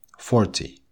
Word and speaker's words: forty: Мы должны помнить о том, что ударение в слове, которое обозначает десятки, всегда ставится на первый слог. Вот сейчас я прочту 4 числительных. Обратите внимание на то, как я ставлю, где я ставлю ударение я ударяю первые слоги. Thirty, forty, fifty, sixty. Если же мы имеем дело с числительными от 13 до 19, ударение всегда идет на последний слог, на вот этот тин.